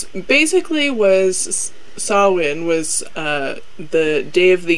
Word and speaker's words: Samhain: Many people have probably seen the word Samhain, which I didn't know until looking this up, is actually pronounced Samhain, because Gaelic ain't make no goddamn sense.